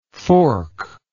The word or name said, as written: forklike